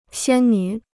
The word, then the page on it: 仙女: xiān nǚ